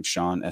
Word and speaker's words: Sean: You see my name, Sean, S-E-A-N.